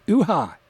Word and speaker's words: Ewha: And we heard a track, I'm just going to say it the way it's spelled, Uha, E-W-H-A, Uha.